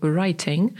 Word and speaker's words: writing: I teraz writing jako pisanie, po brytyjsku writing, a nie jako jazda na koniu lub motorze, czyli riding. – Tak, to prawda. – Ale rzeczywiście po amerykańsku oba te słowa wymawia się tak samo, to taka notka, przypis dolny.